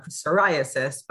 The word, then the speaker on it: psoriasis: the difference between how something might be spelt with a silent P on psoriasis, but it's pronounced with an S.